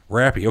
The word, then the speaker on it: rappie: Hold on. R-A-P-P-I-E pie. This sounds like I'm getting raped. Is it a rapey pie? – How do you spell it? – Rappy.